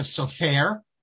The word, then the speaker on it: Sofer: His name is Sofer. You don't even put the accent on the last part, because he himself pronounced, and his family always pronounced it with the accent on the first part.